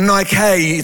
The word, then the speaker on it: Nike: The Greek word for victory is where you get the word Nike or Nike, it's pronounced.